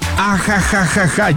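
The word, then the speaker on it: ajajajaja (ахаха): Entonces, se pronuncia allá en Rusia como ajajajaja, ya que la X tiene una pronunciación bastante similar como la J.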